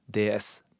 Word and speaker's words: DS: Det var i begynnelsen en spin-off av Citroën, og hentet sitt navn fra deres udødelige klassiker ved samme navn, DS. Bokstavene slått sammen uttales som ordet gudinne på fransk.